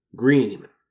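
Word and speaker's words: Gruene: Green. It's pronounced green, but it looks like grün, which, of course, is the German word for green.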